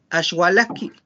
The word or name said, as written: axualajki